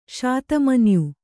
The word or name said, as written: ಶತಮನ್ಯು